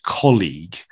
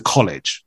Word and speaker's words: colleague: Now, colleague for the two of us is kind of maybe quite easy to pronounce, but in terms of our experience with Spanish speakers, right, it's so often then just changed or adapted or just pronounced maybe slightly incorrectly, and it kind of goes very easily to college, right? – College.